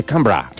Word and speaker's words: Canberra: Que no se dice Canberra, se dice Canberra.